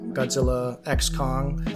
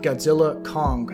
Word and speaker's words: Godzilla x Kong: Or, you know, I just found out it was supposed to be pronounced Godzilla Kong, The New Empire. Like the X is supposed to be silent, which kind of bothers me.